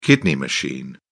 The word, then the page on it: kidney machine: British English